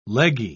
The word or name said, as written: leggy